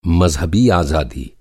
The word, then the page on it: आज़ादी: آزادی